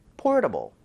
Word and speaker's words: portable: T 사운드 혹은 RT 사운드가 껴있으면 리을 비슷하게. 그 얘기 뭐냐면요, 그냥 편하고 힘 빼고 발음하라는 겁니다. Portable 이렇게 하지 말고요. 한번 try 해보세요. Portable. Portable.